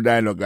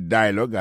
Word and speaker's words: dialogue: Comme n'a pas mis accent, sous bel accent en anglais, sous belle prononciation en anglais, le mot dialogue a gagné deux syllabes là-dedans. Daily Dialogue, alors, yo pesé plus sous premier syllabe là. Daily Dialogue. Alors, accent tombé sous premier syllabe là.